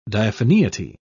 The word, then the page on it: diaphaneity: British English